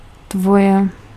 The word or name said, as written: your